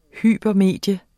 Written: hypermedie

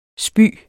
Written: spy

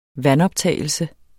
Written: vandoptagelse

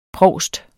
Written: provst

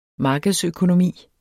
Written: markedsøkonomi

